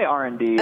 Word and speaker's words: R&D: And do you say R plus D, or am I saying it wrong? – We say R&D. – Okay. – The R plus D is like a signature brand thing.